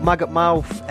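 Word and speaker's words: Maggot Mauf: I'm not just mispronouncing mouth, which is a pet hate of mine. Mouth. Shut your mouth, yeah? It's called Maggot Mouth, M-A-U-F.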